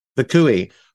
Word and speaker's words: Vacui: Did I pronounce it correctly? – Vacui.